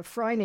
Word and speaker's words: Phryne: Yeah, Phryne. It rhymes with briny.